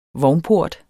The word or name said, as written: VOGNPORTEN